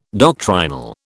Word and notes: doctrinal: British English

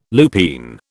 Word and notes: lupine: British English

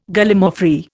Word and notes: Gallimaufry: Indian

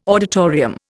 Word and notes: auditorium: Indian

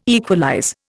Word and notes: equalize: Indian

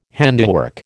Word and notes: handiwork: American English

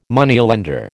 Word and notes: Moneylender: American English

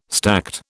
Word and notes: stacte: British English

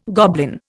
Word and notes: goblin: Indian

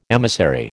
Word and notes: emissary: American English

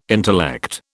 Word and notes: intellect: British English

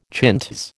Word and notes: chintz: American English

